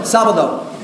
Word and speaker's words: Sábado: Sábado.